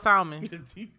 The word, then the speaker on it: salmon: Don't say Salmon because my girl is in the feed and she'll probably be like, because she talk about it, because every now and then I'll pronounce that L. It's tough though, man. Right, right. It's tough to sit there and not leave that L out of there.